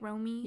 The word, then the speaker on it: Romy: Romy, right? I'm saying it right. Romy. – Yeah. – Romy. R-O-M-Y.